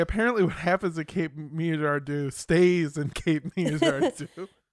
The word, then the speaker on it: Girardeau: And I'm definitely going to pronounce this wrong. I'm so sorry. Girardeau. Cape Girardeau, Missouri.